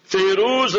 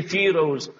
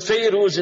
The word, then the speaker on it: Fayruz: Fayruz radiallahu anhu, we say in our dialect, in our languages and vernacular, we say Firoz. So it's fine, Firoz, but Fayruz is the proper pronunciation.